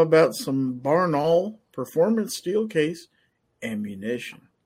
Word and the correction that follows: Barnaul: It's actually pronounced Barnaul.